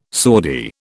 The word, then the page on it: Saudi: British English